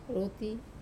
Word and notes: rut: wamesa

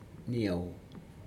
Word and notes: niau: Aneityum

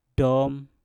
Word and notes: dom: Mpur

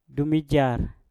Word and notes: dumijar: Mpur

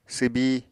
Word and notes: swbi: apatani

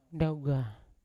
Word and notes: ndaugan: Yawa

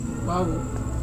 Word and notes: wavu: Serewen

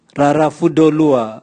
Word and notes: raravudolua: Raga

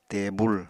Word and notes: tebul: apatani

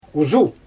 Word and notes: uzu: Xyzyl

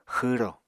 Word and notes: xwro: Apatani

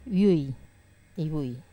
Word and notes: vui: Wamesa

